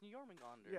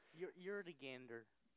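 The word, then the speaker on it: Jörmungandr: I thought it was Jormungandr. – That might be it. – Yeah, it's Jormungandr. – Yeah, I was close. I could never remember how to pronounce it. – Yodogander.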